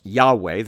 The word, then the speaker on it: Yahweh: Now remember, the name of God is pronounced Yahweh. That's the best I think we can come in our modern times.